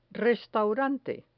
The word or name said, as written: restaurante